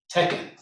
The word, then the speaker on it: Teken: So in Egypt, it is called Tekken, which is spelled T-E-K-E-N. – Tekken.